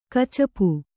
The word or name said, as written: ಕಚ್ಚಪ